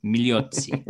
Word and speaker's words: Migliozzi: Migliozzi. – We don't pronounce the G. Sorry. Migliozzi.